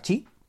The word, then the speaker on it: Qi: Bueno, pues la carga inductiva que es compatible con, los, eh, con el estándar chi, QI, se, escribe, se pronuncia chi, no me preguntes por qué. Yo lo escucho en todas partes como chi y así que yo lo digo así.